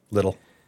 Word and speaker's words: Lidl: That's how the people in Scotland pronounced it, anyway. – I never heard anyone actually pronounce it. – Lidl? – Little. – Little.